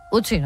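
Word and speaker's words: Ötzi: Otzi, creo que se llamaba, un nombre muy, muy noruego. – Utsi, ¿no? – Utsi, pues sí, no sé, lo pronuncias tú mejor que yo.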